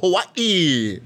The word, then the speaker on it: Hawaii: You got to pause in the middle. Hawaii. Try it. Hawaii. – I'm not trying. – Try it out, bro. – You're pronouncing the H. – You got Hawaii. They don't pronounce the H, brother.